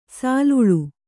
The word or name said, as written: ಸಲೀಲ